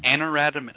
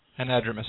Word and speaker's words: anadromous: I can't even pronounce that right, but close enough. – Anadromous. Yep. – Anadromous. Yeah. Anadromous. I always throw an extra A in there.